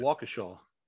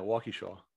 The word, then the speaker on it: Waukesha: Waukesha. – Yeah, you're from that area. – Yeah, Waukesha.